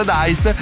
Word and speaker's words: Dice: Tutti gli eventi sono gratuiti, vi ricordo che sono tutti gratuiti, però con prenotazione fino a esaurimento posti sulla piattaforma Dice, che si scrive Dice ma si pronuncia Dice,